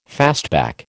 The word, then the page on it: fastback: American English